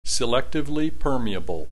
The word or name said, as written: selectively permeable